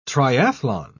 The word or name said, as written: triathlon